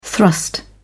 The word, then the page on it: thrust: British English